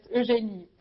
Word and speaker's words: Eugénie: Bueno, sí, Eugène, el nombre es complicado porque es en francés, es Eugénie, pero para ustedes Eugene.